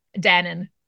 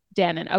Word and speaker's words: Dannon: How do we say his last name? – Dannon. – Dannon. Okay. That's what I thought, but with the 2 A's.